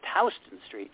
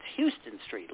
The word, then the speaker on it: Houston Street: It's pronounced, it's spelled H-O-U-S-T, you know, like the city, but it's pronounced Houston Street. Now, if you were reading this online, you would think it's Houston Street, like the town.